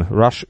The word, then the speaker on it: Russ: Rush übrigens R-U-S-S, also Russia kurz ausgesprochen im Englischen.